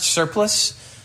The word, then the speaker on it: surplice: I'm saying it, I think it's pronounced surplice, but I think that's confusing.